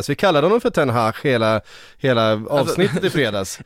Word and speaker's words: Ten Hag: Han heter ju inte Ten Hag, han heter Ten Hag.